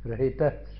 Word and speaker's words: rahita: This rahita, there is visarga, you know. So we have to pronounce a bit of it. Yaha pancha bhuta rachite rahita sharire.